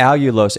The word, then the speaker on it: allulose: So I'm surprised you said Alios. – Allulose. – Allulose. Sorry, I always pronounce it wrong.